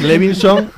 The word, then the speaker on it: Levinson: Es Levinson, lo digo ya bien, y a partir de ahora lo diré mal en todas las demás.